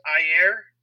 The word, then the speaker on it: Ayer: For the listeners, because I'm obviously super up to par on this, what is the ire cut? – So the ire cut is David Ayer. I think it's actually pronounced Ayer.